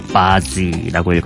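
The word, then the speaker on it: Bazzi: 아, 이 스펠링이 B-A-G-Z-I예요. 그러니까 바지라고 읽어야겠죠.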